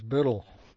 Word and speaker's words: Burrell: The Scottish pronunciation of my name is Burrell.